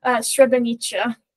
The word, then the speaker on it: Srebrenica: Srebrenica.